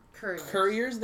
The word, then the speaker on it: couriers: I like the way you pronounced couriers there, because it sounded like it was some sort of quarry and warrior. And now imagine like squid people on bikes couriers? – With axes.